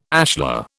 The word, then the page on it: ashlar: British English